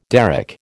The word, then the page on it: derrick: American English